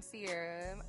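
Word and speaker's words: Cierra: And I'm Sierra.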